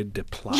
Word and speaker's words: Desplat: And I'm going to butcher the composer's name, but it's Alejandro Desplat.